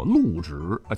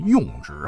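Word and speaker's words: 甪直: 比方说用到的“ 用” 这个字啊，如果在用到的“ 用” 顶上，咱多来个一小撇。哎，咱们就不能念用了哈，要念作路。你像是江苏苏州市吴中区，不是有座千年水乡吗？唤作路直，千万别念成用直啊。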